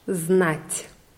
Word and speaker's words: знать: Wir machen weiter mit unserer Lektion 16 und wir lernen heute das Verb wissen auf Russisch znat. – Znat. – Das T am Ende wird weich ausgesprochen, weil da ja ein Weichheitszeichen dran ist.